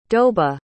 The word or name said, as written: doba